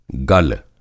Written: गल

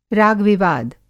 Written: रागविवाद

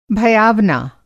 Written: भयावना

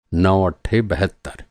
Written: बहत्तर

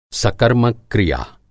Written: सकर्मक-क्रिया

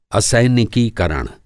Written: असैनिकीकरण

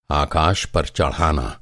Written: आकाश

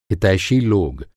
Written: हितैषी

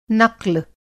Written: नक़्ल

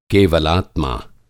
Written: केवलात्मा

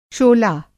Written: शोला

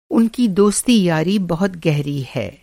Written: दोस्ती-यारी

